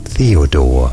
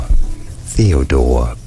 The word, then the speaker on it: Theodore: Theodore. Theodore. Both British and American pronunciations are similar here as Theodore.